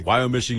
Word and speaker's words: Wyomissing: Headed to the Nicholas Stolfus Homestead in Wyomissing. Am I pronouncing that correctly? – Stolfus. – Berks County. – Wyomissing, you are pronouncing that correctly, yes.